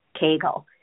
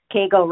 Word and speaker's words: Cagel: Hello, my name is Sandy Cagel, spelled K-E-G-E-L, but pronounced with the long A, Cagel. Cagel rhymes with Hagel. Don't Hagel over Cagel. Just say it with the long A instead of an E, which seems like the correct phonetic pronunciation.